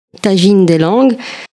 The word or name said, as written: Tajine Lughat